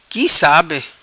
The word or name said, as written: Qui Sabe